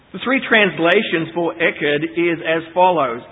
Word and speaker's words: Echad: The word there in the Hebrew is, and I'll spell it for you, E-C-H-A-D. Echad. E-C-H-A-D.